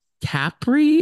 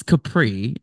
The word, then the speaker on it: Capri: They kept saying Capri. It's Capri, I believe. – Maybe he thought he like was saying it correctly and like in the Italian way. I know the Italians don't say Capri. Capri sounds like something you would say in the great state of Texas where I'm from.